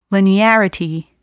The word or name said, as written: linearity